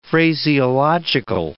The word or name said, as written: phraseological